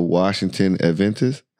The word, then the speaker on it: Washington Adventist: Coach Lyons there, at Washington Adventist. Did I say that correctly? – Yeah. Washington Adventist University.